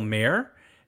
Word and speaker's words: Mayer: It's Michael Mayer, like the guy who runs a city.